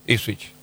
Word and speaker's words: Ipswich: Yo le dije del, y lo quiero decir bien, el Ipswich. Sí, ¿se dice así? – Sí, Ipswich.